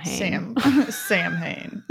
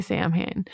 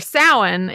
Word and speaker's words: Samhain: So that is, that's how you say it. Don't say Samhain. So it is based, or Samhain is this 3-day ancient Celtic pagan festival that would have occurred sometime around the end of October, 1st of November.